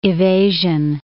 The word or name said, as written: evasion